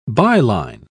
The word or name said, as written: byline